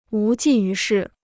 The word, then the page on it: 无济于事: wú jì yú shì